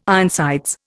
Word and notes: Ironsides: Indian

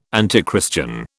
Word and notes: antichristian: British English

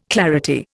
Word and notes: clarity: Indian

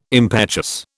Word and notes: impetuous: British English

